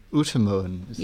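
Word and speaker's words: Utermohlen: You can, One of the most fascinating case studies you had, I thought, was this guy, chap William Utemoen.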